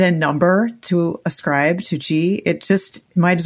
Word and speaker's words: qi: And for listeners, that's spelled Q-I, but qi.